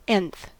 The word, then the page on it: nth: American English